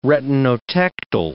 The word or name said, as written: retinotectal